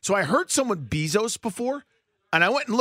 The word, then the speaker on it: Bezos: But it definitely says Bezos. – Correct. – For pronunciation stuff. So I've always just gone with that.